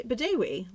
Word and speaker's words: Bedewi: I am here this week with the lovely and talented Lucy Bedewi.